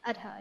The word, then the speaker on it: Adha: Adha, yeah.